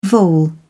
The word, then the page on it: vole: British English